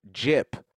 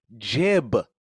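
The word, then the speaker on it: gyp: I've been saying jib with a B instead of pronouncing the P.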